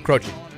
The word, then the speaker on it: Croce: It's why the basketball coach Jim Gross, who spelled G-R-O-C-E, people always called him Croce, and he's like, it's gross.